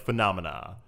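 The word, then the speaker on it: Funomena: It's Phenomena, but spelled with an F-U-N, so it's like Phenomena.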